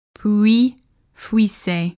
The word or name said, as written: Pouilly-Fuissé